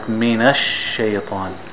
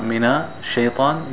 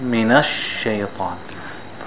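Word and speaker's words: minash shaytan (من الشيطان): And also pay attention when it has a shadda. Like minash shaytan. See, there's two sheen, not just one sheen. So not mina shaytan, minash shaytan.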